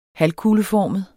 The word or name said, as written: halvkugleformet